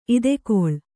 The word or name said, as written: ಇದೆಕೊಳ್